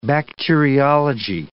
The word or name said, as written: bacteriology